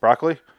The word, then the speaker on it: Broccoli: How do you say his last name? Broccoli? – Broccoli.